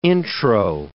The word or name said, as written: intro